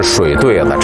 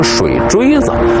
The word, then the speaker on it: 水对子: 这念起来啊，一定得是水对子，查字典也是水对子，但是北京人呢，都念成水锥子。